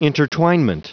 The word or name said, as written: intertwinement